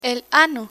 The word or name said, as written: ano